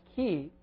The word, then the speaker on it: chi: It's you know, we write it in English as like a CH, you know, pronounced chi.